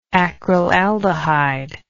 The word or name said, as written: acrylaldehyde